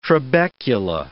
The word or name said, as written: trabecula